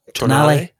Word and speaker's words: Tonale: Tonale.